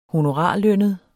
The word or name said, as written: honorarlønnet